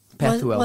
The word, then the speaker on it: Pethuel: Is it Pethuel? – Mm-hmm. Pethuel.